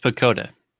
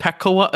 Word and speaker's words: PECOTA: So, what, what I want to start with here is say that it's pronounced PECOTA. Oh, it's I said I said I said Pacoa again.